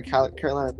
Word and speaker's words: Carolina: has a Calorina Reaper pepper that he's going to buy. I'm uh, sorry, Carolina Reaper.